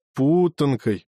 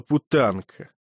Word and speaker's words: путанка: В городе ее называли путанкой. Гюнтер сделал отчетливее, сильнее, чем нужно, ударение на «у». Ему не хотелось, чтобы прозвучала путанка.